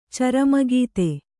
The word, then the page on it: ಚರಮಗೀತೆ: carama gīte; Kannada